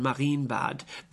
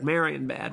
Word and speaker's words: Marienbad: By the way, that word is pronounced in German, Marienbad, but the way we've received it, it's last year at Marienbad.